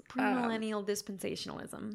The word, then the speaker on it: premillennial dispensationalism: Premillennial dispensationalism.